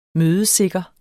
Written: mødesikker

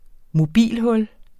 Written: mobilhul

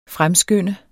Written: fremskynde

